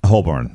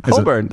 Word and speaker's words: Holborn: I was over in Holborn and all around. Yeah. – Oh, cute. Say it again. – Holborn. Is that right? – No. – Is it Holborn? – Holborn. – Holborn. Okay.